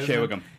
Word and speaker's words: Shea Whigham: Yeah, Shea Whigham.